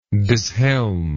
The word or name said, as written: dishelm